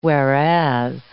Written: whereas

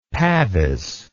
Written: pavis

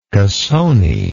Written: cassone

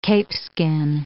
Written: capeskin

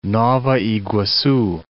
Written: Nova Iguacu

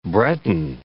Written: Breton

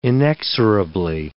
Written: inexorably

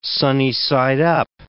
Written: sunny-side up

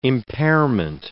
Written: impairment

